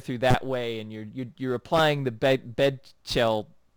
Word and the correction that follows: Bechdel: test. I can't fucking pronounce that. Bechdel test.